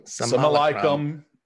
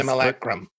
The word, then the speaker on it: Simulacrum: Simulacrum is how it is pronounced, I believe. – Yes, Simulacrum.